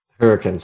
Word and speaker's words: Hurricane: And I'm going to correct your pronunciation. It's Hurricane. – Hurricane. Sorry. – It drives me fucking nuts. It's Hurricane. I'm like, don't try to church it up.